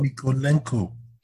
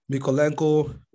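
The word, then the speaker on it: Mykolenko: someone like Milenko, or I know I definitely pronounce that shit well, but Mikolenko. – Shit, now save day. So Mikolenko, Richarlison, obviously, Zaha is pretty obvious.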